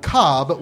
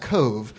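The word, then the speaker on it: Cobh: On the southern end of the country, you'll find the town of Cobb, which is actually pronounced Cove.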